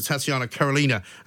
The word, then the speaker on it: Tatiana Karelina: Let's talk to Tatiana Karolina, who is stylist to the stars, I'm going to say.